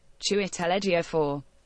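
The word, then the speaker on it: Chiwetel Ejiofor: I'm going for UK pronunciation. – Chiwetelejiofor. – That's a little more like it, right? – Well, and he is British, so right. So that's. Do you think they could pronounce it? That's in the right area. – Yeah. – Chiwetelejiofor. – Whoa! There you go.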